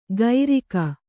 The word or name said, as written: ಗೈರಿಕ